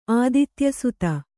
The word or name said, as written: ಆದಿತ್ಯಸುತ